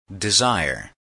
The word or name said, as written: desire